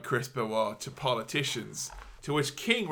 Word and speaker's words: Benoit: Calls Chris Benoit Chris Benoit. – I think that's quite possibly my favourite mispronunciation by Jericho. – Benoit.